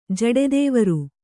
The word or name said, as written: ಜಡೆದೇವರು